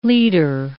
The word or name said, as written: liter